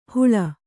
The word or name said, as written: ಹೂಳ್